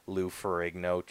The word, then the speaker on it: Lou Ferrigno: I remember sitting on the couch on Saturday mornings watching Lou Ferrigno transform, or I forget his name. – You said it right.